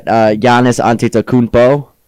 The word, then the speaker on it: Giannis Antetokounmpo: Okay, then I got Giannis Antetokounmpo.